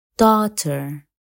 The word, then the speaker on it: daughter: ويمكن أيضا أن يصبح صامتا لما تأتي جي اتش بعد حرف متحرك مثل high daughter though